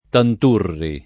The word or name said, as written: Tanturri